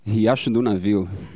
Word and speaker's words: Riacho do Navio: Riacho do Navio.